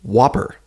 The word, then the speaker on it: WOPR: Whopper. – Could you spell that for me, please? – W-O-P-R.